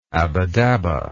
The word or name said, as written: abbadabba